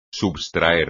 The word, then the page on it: substraer: espagnol